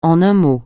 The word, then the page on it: en un mot: français